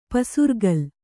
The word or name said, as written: ಪಸಿರ್ಗಲ್